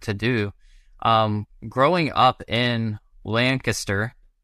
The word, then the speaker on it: Lancaster: Saying it right? Yeah. Yes. Corrected me last time. Got it down. Growing up in Lancaster on a farm, with chickens and all of the things, where did metalcore even come into the picture for you? – It's a great question. And you said it right.